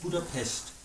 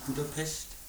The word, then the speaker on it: Budapest: Und die Aussprache ist Budapest. – Ja, genau, mit einem Esch am Ende. Ich ist es so halbwegs richtig, Budapest? – Ja.